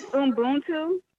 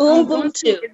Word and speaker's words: Ubuntu: So it's Ubuntu. – And Ubuntu.